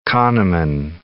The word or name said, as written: Kahneman